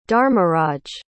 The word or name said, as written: dharmaraj